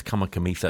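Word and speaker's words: Kamikamica: I think it's Kamakamitha because the C in Fijian is soft. You don't say it as a hard C.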